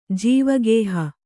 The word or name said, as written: ಜೀವಗೇಹ